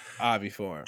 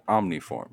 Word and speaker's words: oviform: O-V-I-F-O-R-M. – Can you use it in a sentence? – Egg-shaped. Oviform. – Jimmy's head is very omniform. – Oviform. – Yes, can you spell it? – It is an adjective. O-V-I-F-O-R-M.